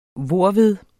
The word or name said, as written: hvorved